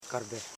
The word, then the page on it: karbe: kar.be; Koro